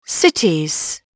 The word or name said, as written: cities